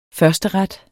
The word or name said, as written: førsteret